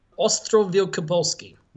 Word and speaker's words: Ostrów Wielkopolski: Ostrów Wielkopolski.